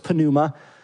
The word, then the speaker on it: pneuma: In English, we have this when the P and the N is together, we just leave the P silent. But in Greek, every letter is pronounced in a word, so it's pneuma.